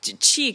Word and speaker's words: qi: So qi can be qi or chi, depending on how you want to pronounce it.